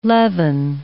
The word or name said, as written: levin